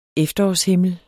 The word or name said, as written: efterårshimmel